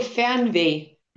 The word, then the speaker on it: Fernweh: So it's called Fernweh.